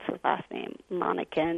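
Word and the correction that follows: Monkkonen: Penty Munkinen? – Yes.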